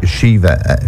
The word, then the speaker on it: Siomhá: It's a girl called Siomhá, Siomhá, which is spelled S-I-O-M-H-A.